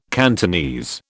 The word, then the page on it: Cantonese: British English